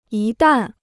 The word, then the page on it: 一旦: yī dàn